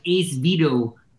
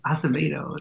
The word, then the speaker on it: Acevedo: Some people call me like Ace Vito. I'm like, it's Acevedo. It's like I get Bishai, and so that's why I put my middle initial so it rhymes.